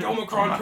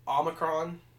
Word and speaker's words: Omicron: And they say it wrong on the news. They say Omicron. It's Omicron.